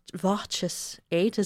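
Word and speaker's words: Voces8: he said it's Voces 8 is how you say.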